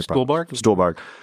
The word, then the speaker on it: Stuhlbarg: Stuhlbarg, who plays the father.